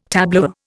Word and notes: tableware: Indian

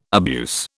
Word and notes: abuse: British English

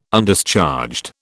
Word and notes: Undischarged: British English

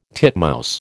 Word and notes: titmouse: American English

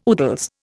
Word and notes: oodles: Indian

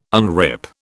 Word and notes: unrip: British English

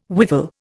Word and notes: whiffle: Indian

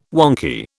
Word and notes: wonky: British English

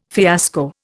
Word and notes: fiasco: Indian

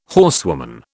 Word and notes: horsewoman: British English

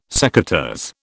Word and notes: secateurs: British English

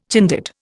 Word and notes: Chindit: Indian

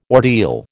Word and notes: ordeal: American English

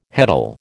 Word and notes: heddle: American English

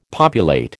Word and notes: populate: American English

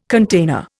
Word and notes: container: Indian

